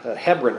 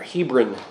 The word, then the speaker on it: Hebron: But Jacob has apparently settled in the valley near Hebron, or Hebron, as we pronounce it here.